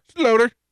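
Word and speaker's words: Sloter: Slowter. I'm just going to call him Slower. – Slowter. I think it's Kyle Slowter. – Kyle Slower. – Slower? – Slow. – There's a T in there.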